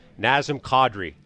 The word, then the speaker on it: Nazem Kadri: Nazem Kadri is how it's supposed to be pronounced.